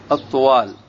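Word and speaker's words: الطُّوال: ويقول الخضري: بضم الطاء وتخفيف الواو كيف سينطق؟ الطوال. ما هو الطوال؟ في بعض الكتب الواو مشددة.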